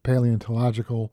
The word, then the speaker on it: paleontological: And, of course, all the paleontological, if I said that right, the sites in Kansas.